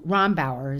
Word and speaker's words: Rombauer: Rombauer is how you pronounce her name, who was the original author of this book.